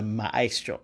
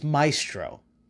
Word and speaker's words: Maestro: For a long time, when I was a kid, and this book came out, and I was reading it, I always called him the Maestro, the Maestro. Because of the way it's spelled, Maestro, M-A-E-S-T-R. I had no idea it was Maestro.